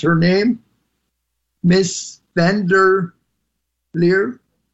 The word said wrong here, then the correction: Ursula von der Leyen: Ursula von der Leyen, yes.